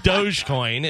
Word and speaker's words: Dogecoin: Dogecoin is how that is pronounced. Like, it's Doge doggy.